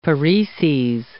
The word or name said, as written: pareses